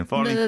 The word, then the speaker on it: falling: 아, 그 G만 빼면 되지 않을까요? falling.